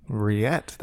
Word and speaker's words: Ryette: Riette? – Yeah. – That's, she likes. How do you spell that? – R-Y-E-T-T-E. – Riette.